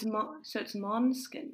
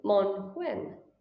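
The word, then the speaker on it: Måneskin: And not only that, but on top of the A, which is pronounced like an O. – Ah, so it's Manskin. – Exactly, Manskin.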